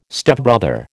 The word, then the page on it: Stepbrother: American English